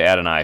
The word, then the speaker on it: Adonai: But yeah, Adonai Mitchell is another guy that, and depending on how you say it, you say Adonai because you could also say Adonai if he's going straight for the reference to the Bible.